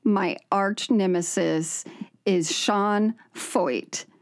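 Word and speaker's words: Feucht: It's pronounced Foyt, and of course, his first name has to be the Sean that's spelled like Sean, but, you know.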